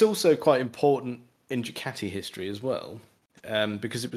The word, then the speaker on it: Ducati: No, it should be Ducati.